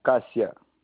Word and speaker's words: Cassia: No, Cassia.